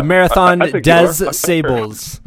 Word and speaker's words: Marathon des Sables: One of the races you mentioned when we were running together was the Marathon de Saab, which I think I'm saying that right. – Yeah. – The Marathon des Sables.